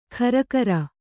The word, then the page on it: ಖರಕರ: kharakara